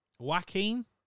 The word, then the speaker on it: Joaquin: Look. Jacqueline. That's not his name. How'd you say his name? Joaquin.